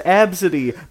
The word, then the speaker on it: Abcde: It's pronounced Absidy, but it's just spelled A-B-C-D-E.